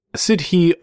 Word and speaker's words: Sidhe: It's S-I-D-H-E. Is that how you pronounce that? – Sure.